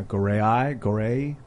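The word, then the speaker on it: Gorée: Is it pronounced Goree? Goree Island? 360 on YouTube to see an immersive 360 video I shot on the island. Cool. – Oh, neat. – Yeah, Goree.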